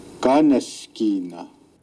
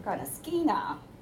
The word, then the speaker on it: Kaneshkina: So accents goes on the first one, and it starts from high and it comes down. Kaneshkina. But when English speakers try to pronounce this word, it sounds like kind of skina.